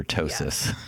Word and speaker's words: ptosis: It's like spelled like the pterodactyl, like PT, ptosis. they just forgot the P, and we're just going to just go with the T for ptosis.